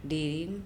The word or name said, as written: Dirim